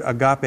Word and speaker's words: agape: And the Greek word for loving here is that word agape. It's spelled A-G-A-P-E. It's like the English word agape.